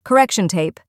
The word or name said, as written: correction tape